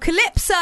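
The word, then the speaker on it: Kylypso: Hugh is here of Calypso. – Hello. – Am I pronouncing that correctly? – It is indeed, yes.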